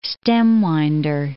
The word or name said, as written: stem-winder